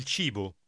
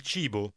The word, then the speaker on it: cibo: When you see C-I together, it's pronounced C. – Listen and repeat. – Il cibo. Il cibo.